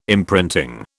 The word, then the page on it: imprinting: British English